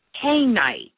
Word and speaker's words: kyanite: The stone that I want you to work with, the particular crystal, it is the crystal called kainite. Kainite, I call it. It's spelled K-Y-A-N-I-T-E.